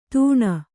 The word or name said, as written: ತಣ್